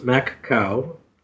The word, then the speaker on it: Macau: P.P.S., the little city in China where we live is called Macau, pronounced Mac-cow. Mac is in McDonald's and cow is in, well, cow.